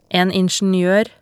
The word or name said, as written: en ingenioer